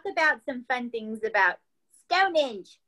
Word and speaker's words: Stonehenge: Okay, so we're from America, and we say Stonehenge, and they say Stonehenge. – Yeah, they say it different. – Their accent is on the second syllable, where ours is on the first, and it's with every word they say, so it's like Stonehenge.